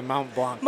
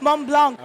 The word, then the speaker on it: Mont Blanc: No, I think we pronounce it Mont Blanc. – Mont Blanc. – Okay. – I'm pronouncing it the French way.